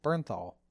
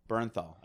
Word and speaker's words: Bernthal: Oh, Bernthal. Bernthal. I can never say his last name right. Bernthal.